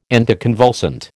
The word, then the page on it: anticonvulsant: American English